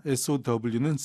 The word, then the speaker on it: sew: 이게 저 바느질하다, 이 SEW와 씨를 뿌리다, SOW는 sow라고 발음이 같다 그랬죠.